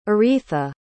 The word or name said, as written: aretha